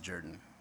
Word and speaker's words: Jordan: Name is spelled like Jordan, but he always pronounced it Jordan.